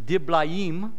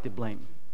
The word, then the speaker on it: Diblaim: Actually, it's pronounced Diblaim, but it just looks like Diblaim.